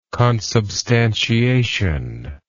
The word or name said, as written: consubstantiation